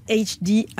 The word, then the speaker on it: HD: HD, prononcé HD à l'anglaise.